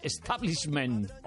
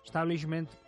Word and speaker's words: establishment: Pronunciado en inglés sería comenzando por la S. Establishment, como les había dicho, establishment. En español, establishment. La paradoja es que tenemos de esta voz inglesa la adaptación en español en nuestro diccionario y empieza por E, no por S. Establishment. SH en el medio, establishment, pues nosotros no pronunciamos la SH.